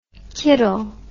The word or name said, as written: kiddle